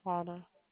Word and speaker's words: Quanna: Quana. – Spell that, please. – Q-U-A-N-N-A.